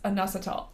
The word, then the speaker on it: inositol: I always have trouble pronouncing that, but it starts with an I and it's pronounced inositol.